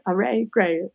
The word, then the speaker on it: Arey: And I'm like, no, it's not Arey. It's Arey gray. It rhymes.